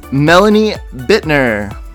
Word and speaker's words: Melanie Bitner: It is my honor and privilege to introduce my guest today, an old friend, acquaintance from the Invisible Children days, Melanie Bitner.